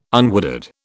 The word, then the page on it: unwooded: British English